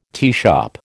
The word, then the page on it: teashop: American English